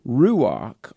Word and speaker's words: ruach: Now, the word spirit there is a Hebrew word, ruach, R-U-A-C-H, if I'm pronouncing it correctly, which is debatable, but R-U-A-C-H, ruach.